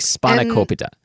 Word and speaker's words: spanakopita: My big question was, is it copita or copita? And the answer is it's kind of in between, but a little closer to cope than cop. So, like, spanakopita.